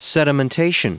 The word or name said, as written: sedimentation